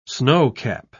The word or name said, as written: snowcap